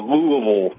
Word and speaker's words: Louisville: And it's Louisville, by the way. Louisville, Kentucky. – Louisville. As I understand, there's a bit of an argument as to pronunciation, even right in the midst of Louisville. – Yeah, if you're born from here, it's Louisville.